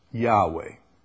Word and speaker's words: Yahweh: The Hebrew letters YHWH, Yod, He, Wah-Hey, YHWH, is probably best pronounced Yahweh, some Yahweh.